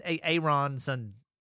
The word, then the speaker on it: Aaronson: His name's Aaronson. Brendan. – No, does he pronounce it Aaron? – It's like Aaron and then Sin. So it's like Brendan Aaronson.